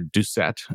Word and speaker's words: DUCET: This data is called the Default Unicode Collation Element Table, or DUCET. I'm going to go with a soft C.